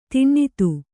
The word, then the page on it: ಧನದ: dhanada